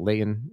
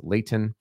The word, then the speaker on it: Layton: This evening, we have the 4A championship between Cottonwood and Layton Christian Academy. We also have the 6A semifinal games. We've got Corner Canyon versus Layton. Remember, Utah, pronounce your T's. It's not Layton, it's Layton.